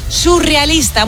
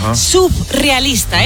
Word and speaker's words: surrealista: Y por otro lado, también decimos mal surrealista. Muchas veces la gente dice, y esto me pone muy nerviosa, subrealista.